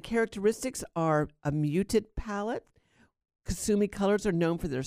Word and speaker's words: Kusumi: Kusumi, and that's spelled K-U-S-U-M-I, Kusumi.